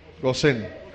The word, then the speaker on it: Gosén: José lo sacó, lo rescató y los llevó a la tierra de Gosén. ¿O Gosen? Gosén. Ahora que lo dije bien.